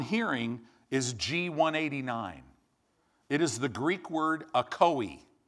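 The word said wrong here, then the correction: akouo: right? It means, it literally, or I'm sorry, let me say this right, akuo. That's the right pronunciation.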